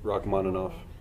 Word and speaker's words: Rachmaninoff: Rachmaninoff.